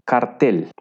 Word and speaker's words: Kartell: Bueno, eh, tiene un origen alemán y la palabra alemana es cartel. Básicamente se pronuncia igual, pero se escribe K-A-R-T-E-L-L.